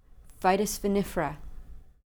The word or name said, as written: vitis vinifera